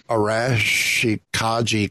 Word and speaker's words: Arashikage: Watch Brothers in Arms as part of the Arashkanaj. God, I never say this right. – Yeah, it's a tough one. – Arashikage clan. There we go.